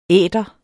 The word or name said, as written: æter